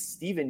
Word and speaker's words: Stefan: And then one guy that I had on my season-long team and I dropped him, I think it's Steven Yeager, even though it's spelled Stefan Yeager.